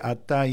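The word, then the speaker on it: Ata (אתה): Pero la segunda vez, la del medio, está escrito sin la letra Hei final. Se lee Ata igual, pero le falta la Hei final, que es como una muda que se pone al final de muchas palabras.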